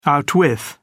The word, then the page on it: outwith: British English